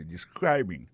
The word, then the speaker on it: describing: il a prononcé mot de describing accent tombé sur deuxième syllabe là ça veut dire mot ça gain trois syllabes describing so, I'll be talking about describing your job history mais pas parler sous description travail.